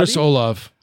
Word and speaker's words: Olave: Like he's ahead of, he's behind Chris, how is it pronounced? Olave? – Chris Olave. – Love Olave. Olave, okay.